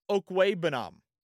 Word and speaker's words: Okwuegbunam: Albert Okwuegbunam. That's how you say it, by the way. Actually, I think that's a little over enunciating. Albert Okwuegbunam, I think is how you say it.